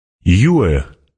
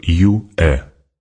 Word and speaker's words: Юэ: Юэ произносится как Юэ.